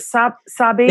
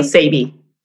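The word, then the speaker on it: Sabe: Is it Sabi? – No, Sabi. – Sabi. – It rhymes with baby.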